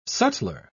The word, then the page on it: sutler: British English